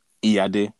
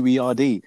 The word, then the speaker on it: Iyade: And I'm gonna even say it with the accent, okay? So, get prepared, innit? Iyade. That's how you actually say my name, okay? But obviously, when I came here, they couldn't say my name. So, it changed to Iyade.